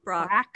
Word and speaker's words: Brach's: I don't know. Is that how you pronounce it? – Brock. – Brock. – Oh, I always thought it was Brock.